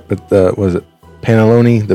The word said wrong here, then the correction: panettone: the cake bread panalone? Is that how you say it? That's what. – Panettone, isn't it? – Yeah, panettone.